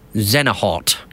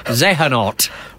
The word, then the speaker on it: Xehanort: and it's called Xenahort. No, Xehanort is the name of the character, but I pronounced it right all the way through the video. It's a 10-minute video, and then at one point I said Xenahort, and oh, the abuse.